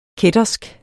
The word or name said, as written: kættersk